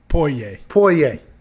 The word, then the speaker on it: Poirier: I see how it's spelled. – Yeah. – Mm-hmm. How is it Poirier? – Yeah. – Poirier. That's Poirier. He's a bad dude.